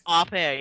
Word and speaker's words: au pair: Au pair? – Au pair, yeah.